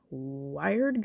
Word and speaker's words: Wyrd: But he's also written stuff like Weird Girl. It's spelled W-Y-R-D, girl. Weird Girl, yeah.